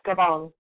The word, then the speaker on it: Gabon: She always believed she would one day be selected and was for Survivor Gabon. Is that how you pronounce it? – Yes, Gabon. – Gabon.